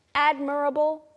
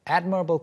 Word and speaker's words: admirable: 네, quality라고 하면 어떤 사람의 자질을 말하는데 admirable quality라고 했습니다. 감탄스러운, 존경스러운 면. Mm. 그래서 지금 사란 씨의 발음 좀 특이하긴 했어요. – Yes, she said admirable, but it's admirable. – Admirable, admire에서 왔기 때문에 이제 admirable이라고 하는 분들이 좀 있을 텐데 그리고 mm-hmm.